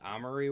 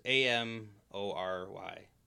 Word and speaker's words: Amory: The the Armory Wars. It's uh, Armory. – No, it's A M O R Y. – Um, A M. Uh, Amory. Amory, I think is how it's supposed Amory to be.